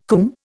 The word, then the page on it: cwm: Indian